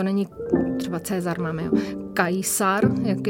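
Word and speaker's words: Caesar: Takže eh, v západní Evropě se, se, se vyslovuje kikero, kajsar, takhle.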